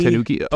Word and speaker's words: Tanuki: You have Greater Tunkai, I think is how you pronounce it. – Tanuki, maybe? – Tanuki. Oh, yeah, it's a different one. – It's from Kamigawa, so it's got to be Tanuki.